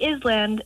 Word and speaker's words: island: Why does island have an S in it, and why is it pronounced with a long I?